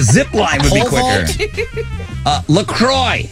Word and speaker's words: La Croix: Pronounced La Croix.